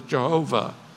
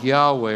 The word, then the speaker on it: Yahweh: Those four letters we mispronounce as Jehovah. It probably was pronounced more like Yahweh or Jahweh.